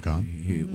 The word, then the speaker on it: Kahan: Noah Kahn. – What did I say? – Noah Kahn. – Is it Kahan or Kahn? – It's pronounced Kahn. It's spelled K A.